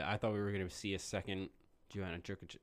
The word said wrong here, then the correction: Jędrzejczyk: I can't say her name. – Joanna Jędrzejczyk. – Thank you.